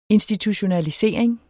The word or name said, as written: institutionalisering